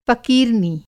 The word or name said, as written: फ़क़ीरनी